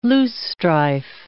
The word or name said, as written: loosestrife